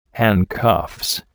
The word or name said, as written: handcuffs